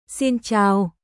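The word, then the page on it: Xin chào: シン・チャオ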